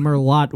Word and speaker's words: Merlot: That's yeah, Merlot, first of all. – Is it? – Yeah. – Did you pronounce the T? – My buddy Otto told me about it, and he sips on Merlot weekly.